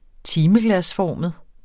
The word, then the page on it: timeglasformet: -ˌfɒˀməð